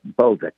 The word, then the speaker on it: Bolduc: And I hope that, pronounce his name for me, Bolduc, is that right? – Bolda? – Yeah, Bolduc, the general running for Senate in New Hampshire, the special forces general.